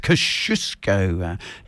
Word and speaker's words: Kosciuszko: Midnight Oil from the album Red Sails in the Sunset, and Kosciuszko, or as it should be pronounced, Kosciuszko. That is the Polish way of saying it.